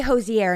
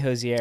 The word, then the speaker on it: Hozier: I say Hozier, and it's not. It's In Pittsburgh, we say Hozier. – Hozier. That's what I would say, but it's Hozier.